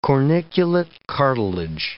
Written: corniculate cartilage

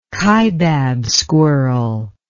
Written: Kaibab squirrel

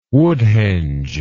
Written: woodhenge

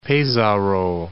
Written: Pesaro

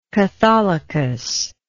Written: catholicus

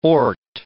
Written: ort

